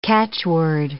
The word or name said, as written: catchword